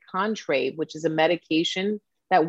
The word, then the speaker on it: Contrave: Contrave. Is that correct? Did I say that correct? – You said it correctly.